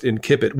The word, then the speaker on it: incipit: I guess is that close enough to on the previous episode you were very adamant about the fact that it's pronounced incipit, which it is. If you're a classicist in Latin, you are always going with the hard consonants, right? – That's the way I was taught, at least.